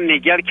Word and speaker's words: Niguel: In Laguna, is it Nigel or Nigel in California? – Laguna Niguel, California, and you pronounce it very well.